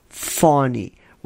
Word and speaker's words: Fani: Midnight for his tryst with the seductress, Fanny. Oh, Fanny, can't come up with a better one than that, huh? I mean, the pun writes itself. Excuse me, Fawny, Roy.